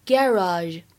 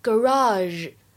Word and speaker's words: garage: 而么我这个单词呢，需要强调一下，它的话呢，单词的发音比较多，在英式英语当中呢，一般来说读作 garage garage，美式当中呢会读成 garage garage。或者呢，像我们今天美剧当中读成 garage 也是可以的。